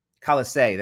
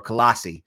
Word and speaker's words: Colossae: So these are the things that are happening in Colossae, uh, uh, Colossae, that's how you say it, yeah, or Colossi.